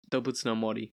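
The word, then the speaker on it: Dōbutsu no Mori: Am I saying that right? Close enough? – Dobutsu no Mori. – Yeah. Yeah, that's what I said.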